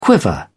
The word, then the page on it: quiver: British English